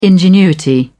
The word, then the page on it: ingenuity: British English